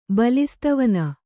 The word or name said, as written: ಬಲಿಸ್ತವನ